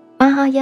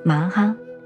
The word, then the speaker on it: 摩诃: 玛哈呀就是大乘，我们念摩诃般若波罗蜜多，就是麻哈。